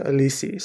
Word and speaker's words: Alice Braga: I know the name is spelled out like Alice Braga, but I was watching, I think a show or someone just mentioned it, and they pronounce it Alisi. So I'm going to go with Alisa Braga.